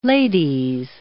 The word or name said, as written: Ladies